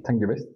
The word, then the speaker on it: Sangiovese: Ahora sé que esta uva, Sangiovese. – Sangiovese.